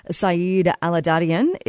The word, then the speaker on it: Saeid Allahdadian: UBC postdoctoral researcher Saeid Allahdadian is joining us on the line.